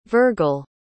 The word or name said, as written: virgle